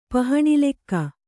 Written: ಪಹಣಿಲೆಕ್ಕ

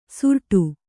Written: ಸುರಟು

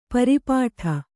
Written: ಪರಿಪಾಟಿ

